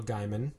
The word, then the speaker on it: Gaiman: He was saying that he was having a conversation with Neil Gaiman. – And, you know, it's Gaiman, right? Like, it's gay man. Like, gay man. It's Gaiman. Neil.